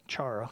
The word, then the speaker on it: chara: Now, the words joy that we're talking about in Greek, it's the Greek word chara, okay, pronounced different ways depending upon which version you take, but chara, okay, C-H-A-R-A. And that Greek word can be translated many ways.